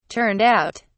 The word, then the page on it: turned out: British English